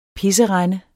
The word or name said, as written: pisserende